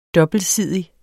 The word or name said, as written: dobbeltsidig